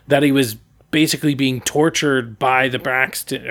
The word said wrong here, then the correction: Braxian: Am I saying it right? Oh, Bra- okay. No, it's Braxian.